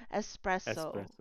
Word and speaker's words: espresso: No, there's no X in espresso. – And that is not from Spain. You don't need to say espresso like that. It's just S. Espresso.